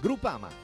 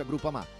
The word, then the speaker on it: Groupama: Groupama. Ah no, si pronuncia Groupama.